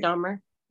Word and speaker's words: Dahmer: Dahmer? – Jeffrey Dahmer.